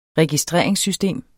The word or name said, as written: registreringssystem